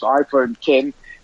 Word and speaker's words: iPhone X: And I don't know about you, Stephen, but iPhone X pronounced iPhone 10.